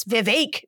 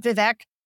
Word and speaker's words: Vivek: And they were like, no, it's Vivek, not Vivek.